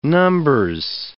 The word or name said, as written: Numbers